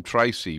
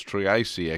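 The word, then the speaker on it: Treacy: They keep on calling him Tracy, but it's Triesi actually how it's pronounced.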